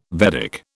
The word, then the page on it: Vedic: British English